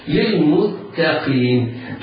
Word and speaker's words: للمتقين: للمتقين، التاء جاورت القاف والقاف حكمها التفخيم، فيتحرز القارئ من تفخيم التاء لمجاورتها الحرف المفخم، فلا يسبق لسانه ويفخمها فينطقها قريبة من الطاء أو ينطقها طاء. هذا لحن جلي لا ينبغي للمبتدئ القارئ أن يقع فيه، وإنما يتحرز من التفخيم فينطق بالتاء مرققة، ينطق بعدها بالقاف مفخمة.